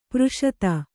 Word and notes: ಪುರುಷಾದ: puruṣāda

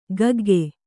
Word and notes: ಗಗ್ಗೆ: gagge

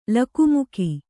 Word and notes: ಲಕುಮುಕಿ: lakumuki

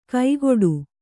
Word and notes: ಕೈಗೊಡು: kaigoḍu

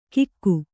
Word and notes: ಕಿಕ್ಕು: kannada

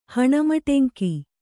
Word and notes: ಹಣಮಟೆಂಕಿ: Kannada

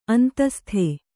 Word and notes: ಅಂತಃಸ್ಥ: antahstha

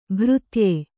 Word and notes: ಭಾರತೇಯ: bharatēya

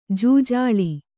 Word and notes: ಜೂಜಾಳಿ: Kannada

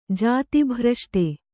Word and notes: ಜಾತಿಭ್ರಷ್ಟ: jāti bhraṣṭa

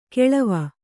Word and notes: ಕೆಳವ: keḷava

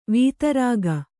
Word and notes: ವಿದಾರಕ: kannada

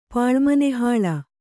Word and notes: ಪಾಳ್ಮನೆಹಾಳ: kannada

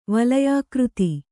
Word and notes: ವಲಯಾಕೃತಿ: Kannada

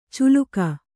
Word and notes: ಚುಲುಕ: culuka

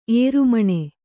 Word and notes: ಏರುಮಣ್ಣು: ērumaṇṇu

